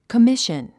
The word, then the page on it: commission: kəmíʃən; コミッション